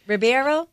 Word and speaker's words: Ribeiro: How do you pronounce his last name? Ribeiro? – I think so. Ribeiro.